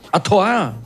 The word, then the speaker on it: Artois: Stella Artois. Atoar? Atoar. E por que está escrito Artois? Porque é assim que se escreve em francês.